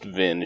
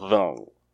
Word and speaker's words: Vin: Mistborn has a bit of a French thing going on, which again, nobody pronounces, like including in the audiobooks. Like Vin is just Vin. She's not Vong.